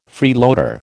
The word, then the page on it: freeloader: American English